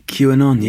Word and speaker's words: QAnon: QAnon, yeah.